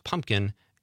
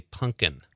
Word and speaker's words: pumpkin: He says, pumpkin is supposed to be pronounced pumpkin. It drives him crazy when people say punkin.